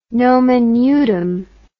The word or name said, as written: nomen nudum